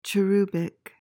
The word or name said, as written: cherubic